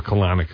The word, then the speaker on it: Kalanick: And thank you for pronouncing it Kalanick, because I do also.